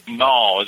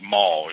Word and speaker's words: mal: So mal is almost like mall, you know. M A L L. – Okay. – You pronounce the A a little bit more instead of O, you know, like a mal. – Mal. – Yeah, like mouth.